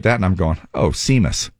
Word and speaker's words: Seamus: And so then today, now I'm going to spell out this guy's name, and I want you to tell me how you would pronounce it, okay? – Okay. – S-E-A-M-U-S. – Seamus. – Oh, son of it's a... It's an Irish name.